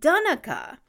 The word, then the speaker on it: Donnacha: T-A-D-H-G is pronounced Tig, and I hope I did that right, and D-O-N-N-A-C-H-A is pronounced Dunica.